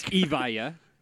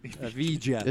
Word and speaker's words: Evija: How do you say it? It's Evia. – Elvira's the Koenigsegg. – Evija? – Evija.